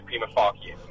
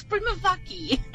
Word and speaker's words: prima facie: It's prima facie. – Prima what? – Tabo, are you okay? – Good morning, Nick and team. Wow. It's pronounced in a Latin-Italian way, prima facie.